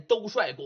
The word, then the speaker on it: 兜率: 那再往下那个字啊，哎，多音字，读帅又读绿，所以呢你说这是兜绿公还是兜帅公？有人说了，应该念兜帅公。